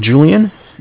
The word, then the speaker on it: Julian: My name is Julian. It's J-U-L-I-A-N.